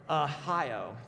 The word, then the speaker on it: Ahio: How do you say Ohio with an A? It's just really hard. Ahio, there you go, was walking in front of it.